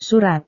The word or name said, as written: surat